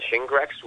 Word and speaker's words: Shingrix: And there's the Shingrix, which is basically the recombinant vaccine.